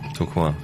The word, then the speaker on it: Tourcoing: Ja, Tim, Tourcoing, richtig.